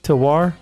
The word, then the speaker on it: terroir: is that how you spell it or pronounce it? T E R R O I R. Tawar, Just like wine.